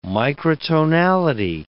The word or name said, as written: microtonality